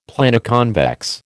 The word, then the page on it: Planoconvex: American English